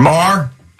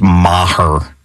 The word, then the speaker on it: Maher: Mr. Maher? That's actually, I'm sorry, it's Maher. Two syllables.